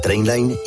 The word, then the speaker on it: Trainline: Se escribe mejor precio garantizado. Se pronuncia Trainline. Descarga la app de Trainline y ahorra comparando todos los trenes y tarifas.